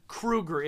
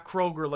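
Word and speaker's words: Kroeger: And also, it's pronounced Kruger. It is Chad Kruger, by the way. It's pronounced Nickelback. – No, his, by the way, his last name is pronounced Kruger because he. – Oh yeah, he prefaced he po- that. He, he posted about it because he was like, "Look, he goes, my last name is not Kroger like the goddamn store.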